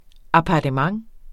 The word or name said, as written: APPARTEMENTER